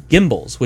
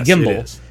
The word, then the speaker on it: gimbal: But yeah, in Expanse, it really bothers me because the first three or four books, they call them gimbals, which is the right way to say a gimbal. – Yeah. – Then later on in the books, they say gimbal. And I'm like, did you spell it with a fucking J? Because it's not a gimbal. It's a gimbal. It didn't fucking change GIF.